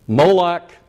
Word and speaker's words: Molech: Molech or Moloch, either pronunciation is correct, and Chemosh and Molech.